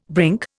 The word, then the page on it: brink: Indian